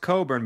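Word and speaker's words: Cockburn: Kofi Coburn, which I'm surprised it's pronounced Coburn,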